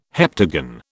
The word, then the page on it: heptagon: British English